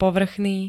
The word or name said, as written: povrchný